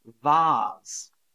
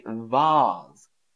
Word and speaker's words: vase: Vase. Vase. So we don't say vase. In British English, we say vase, vase, a vase. So Americans say vase, I say a vase.